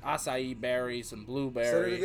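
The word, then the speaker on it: açaí: I believe that one's Portuguese. – Acai. – I mean, that's how you pronounce it.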